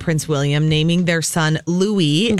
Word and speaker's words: Louis: Formally announce to everybody that it is Louis, not Louis. That is the pronunciation according to People magazine. They have a whole headline, Louis or Louis. Here's how to pronounce the royal baby's name. Yes, Americanized pronunciation is Louis. The French pronunciation is Louis, and the answer is the latter.